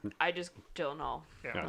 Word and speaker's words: Gyllenhaal: The actual pronunciation of... – Gyllenhaal? – Gyllenhaal. – No one says it like that. – Because, well, the Swedish do.